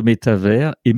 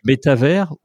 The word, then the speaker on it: métavers: Est-ce que tu pourrais nous expliquer en détail et très concrètement ce qu'est le métavers et même démarrer par comment on prononce ça. – Mm-hmm. On dit métavers ou métaverse, puisque les deux, quand on va sur Internet et qu'on mm mm-hmm. googleise métavers, bah, les, les deux mots ressortent.